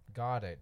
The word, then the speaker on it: Goedert: Dallas Goedert. – Goddard, there we go.